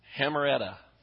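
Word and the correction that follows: Hamartia (ἁμαρτία): I'm not hitting the pronunciation, I'm not hitting the emphasis right. It's Hamartia. Okay, that's the word in Greek.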